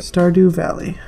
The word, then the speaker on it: Stardew Valley: Stardew Valley.